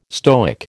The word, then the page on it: Stoic: American English